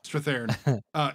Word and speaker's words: Strathairn: Strathairn. Yeah. – Strathairn.